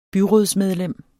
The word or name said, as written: byrådsmedlem